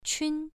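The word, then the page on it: qun: tone 1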